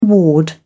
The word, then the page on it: ward: British English